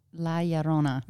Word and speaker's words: La Llorona: La Llorona. – Because mm-hmm. – she's in a statue it. And it's not going anywhere. – No. Yeah, no, that's a different La Llorona.